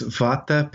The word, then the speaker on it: Vata: Yes, Vata, Pitta, and Kapha.